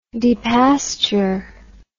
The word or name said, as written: depasture